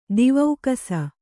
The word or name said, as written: ದಿವೌಕಸ